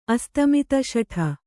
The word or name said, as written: ಅಸ್ತಮಿತಶಠ